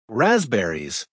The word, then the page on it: raspberries: American English